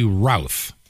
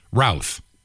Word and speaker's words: Routh: Talking about Ryan Wesley Routh, that rhymes with South Mouth Routh.